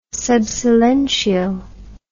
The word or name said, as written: sub silentio